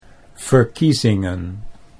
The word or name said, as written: verkiezingen